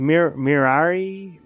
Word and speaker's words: Mirai: Mirai, yeah, it means like future in Japanese.